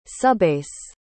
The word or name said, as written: subbase